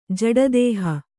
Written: ಜಡದೇಹ

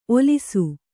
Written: ಓಲಿಸು

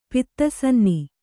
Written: ಪಿತ್ತಸನ್ನಿ

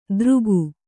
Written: ದರಕು

